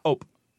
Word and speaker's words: hop: It comes from H-O-P, ope. It sounds like ope in French because you don't pronounce the H. So allez-oop, allez-oop, like that. So it sounds like up.